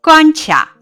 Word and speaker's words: 关卡: 本单元出现的多音字如下：卡组词卡壳、关卡；卡组词卡片、卡车。例句：关卡那里停了好多卡车。